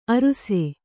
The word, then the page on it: ಅರುಸೆ: aruse